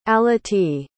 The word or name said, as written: aletea